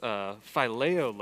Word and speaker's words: phileo: This is phileo love. I checked my pronunciation from Young, so we're good. Phileo love, a love between friends, between equals, within community.